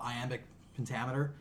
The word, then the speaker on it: iambic pentameter: Because it's all iambic pentameter.